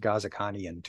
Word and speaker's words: Ghazikhanian: But they pronounce it Ghazikhanian to us, so.